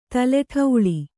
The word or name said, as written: ತಲೆಠೌಳಿ